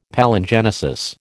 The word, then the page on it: Palingenesis: American English